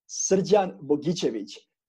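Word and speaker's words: Srđan Bojičević: I can tell you, like, my real name, I mean, let's say how you can pronounce it really correctly, which is a little bit hard because it's Serbian name, it's Srđan Bogičević, but my Canadian friends are calling me Serge.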